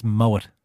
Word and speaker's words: Moët: It's Moët. – Moët? – He's Dutch. – Really? – Even though it's a French champagne, the name is Dutch, so you pronounce the T.